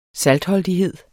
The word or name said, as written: saltholdighed